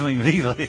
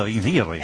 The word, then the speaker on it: Bembibre: ¿Qué es lo que pasa en este estudio de radio que no sabéis decir Bembibre? – ¿Cómo? – ¿Cómo se dice? No sabéis decir. No, si es lo más sencillo del mundo, Bembibre. Vamos a ver, señorita Navarro, por favor, repita conmigo, Bembibre.